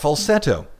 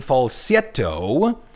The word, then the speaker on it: falsetto: It's called falsetto. The Italian pronunciation falsetto, it's a diminutive of falso, meaning false.